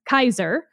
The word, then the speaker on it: Caesar: And of course, as anyone who has ever learned any Latin knows, Julius Caesar, for instance, there is no J in Latin. It would be Julius Kaiser.